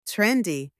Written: trendy

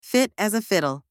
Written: fit as a fiddle

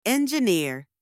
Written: engineer

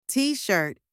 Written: t-shirt

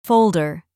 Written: folder

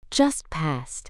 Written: just past

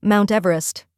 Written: Mount Everest

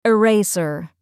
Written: eraser

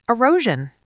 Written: erosion

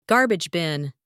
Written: garbage bin

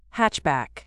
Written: hatchback

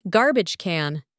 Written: garbage can

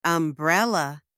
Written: umbrella

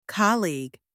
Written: colleague

